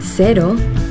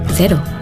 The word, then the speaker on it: Cero: In Yucatan, the local Mexican surfers pronounce cero, cero. During the warm summer evenings in bars around the coast of Nayarit, you'll hear them say cero. In New Zealand, zero alcohol Corona cero is pronounced cero.